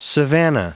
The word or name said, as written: savanna